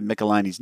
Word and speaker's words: Michelinie: He said, regarding David Michelinie's name, I've been pronouncing it Michelinie ever since seeing a letters column explaining that it rhymes with pickle my knee.